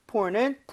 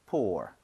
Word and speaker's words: pour: pour는 불쌍한 이런 느낌을 좀 네. – 가지고 있는데, 발음 자체는 차이가 없습니다. 그래서 pour, pour. 똑같이 해주시면 돼요.